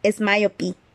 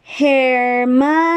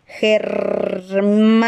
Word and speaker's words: Hermione: Él seguía diciendo es, miop, es myopi. Hermione, decía ella despacio y claro. Hermione. Mm, se acerca bastante, aprobó ella mirando a Harry sonriendo.